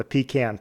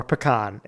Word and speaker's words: pecan: I had some appetizers listed, but I knew that you guys would leave me the, depending on how you pronounce it, the pecan pie. – Oh, yeah. Okay. Yep. – Mm-hmm. You know, yeah. Or pecan if you're Jenna or, you know, a lot of other people. But yeah, that'll round out my top five.